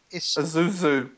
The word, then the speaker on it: Isuzu: Isuzu.